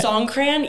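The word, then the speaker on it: Songkran: So a few years ago when I went, the Song Krang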